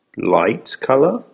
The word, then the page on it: light colour: American English